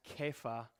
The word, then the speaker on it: Kepha: It's actually pronounced Kepha. If you pronounced it in the Aramaic pronunciation, when it gets written in English, it gets the C, so we would naturally pronounce it Cephas.